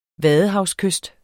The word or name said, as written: vadehavskyst